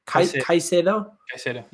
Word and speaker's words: Caicedo: Caicedo, yeah.